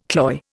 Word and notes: cloy: Indian

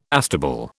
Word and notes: astable: British English

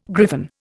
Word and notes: griffon: Indian

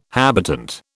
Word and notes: habitant: British English

